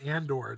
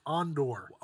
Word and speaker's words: Andor: Maybe Andor answer. I haven't seen Andor, so if everyone dies, I'm sorry. But oh, you're cringing me right now. It's not Andor. It's Andor.